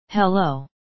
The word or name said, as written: hello